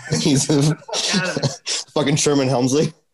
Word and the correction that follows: Hemsley: That one, I get Sherman Hemsley. All right, said it wrong too.